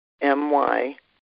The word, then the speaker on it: Myrna Lou: And so my mother named me Myrna Lou, and it looks like my when you see M-Y, but it's Myr.